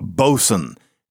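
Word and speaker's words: boatswain: Hence, also those weird nautical words, you know, bosun, and you find out that it's spelled boat swain. Well, it's just been so long, the accent is up front, it's said quickly, things start kludging together. Next thing you know, you've got a bosun or a forecastle.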